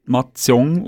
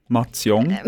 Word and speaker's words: Mahjong: Eine aufmerksame Hörerin hat uns noch darauf aufmerksam gemacht, das Spiel Mahjong, spricht man Mahjong aus. Mahjong.